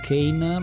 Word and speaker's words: Kane: Billy che tra l'altro ha una doppia pronuncia, perché viene detto Billy Kane, ma viene detto anche Billy Khan. Quindi Kane può essere forse per il fatto che ha un bastone, quindi Kane in inglese, ma molto spesso si dice anche Billy Khan per la pronuncia originale.